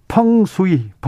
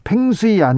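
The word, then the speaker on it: 펑수이 (风水): 재봉정님께서 풍수는요. 펑수이, 펑수이입니다. 팽수이 아니고요. 네, 펑수이 이 중국 중국말을 중국말을 그대로 영어로 써가지고 펭수이 아닙니다.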